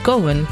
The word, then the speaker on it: Cohen: Leg je die klemtoon echt zo, Cohen? Ik dacht dat het Cohen is. Oké, ja, ik heb altijd... Het kan ook Cohen zijn.